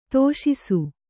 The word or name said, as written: ತೋಷಿಸು